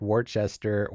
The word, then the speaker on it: Worcester: It's Worcester. – It's like with a U sound. So it's W-O-R, and it's actually kind of a whoosh.